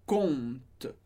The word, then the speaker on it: compte: Seja no Facebook, seja no banco, a gente sempre vai dizer un compte. Conta, a gente não pronuncia esse P.